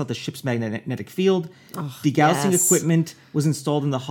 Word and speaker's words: degaussing: But it was it wasn't technically invisible by the use of degaussing. I don't know how to pronounce it. – D-E-G-A-U-S-S-I-N-G.